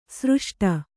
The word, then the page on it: ಸೃಷ್ಟ: sřṣṭa